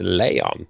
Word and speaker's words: Leon: It's a Leon. That's how they say it.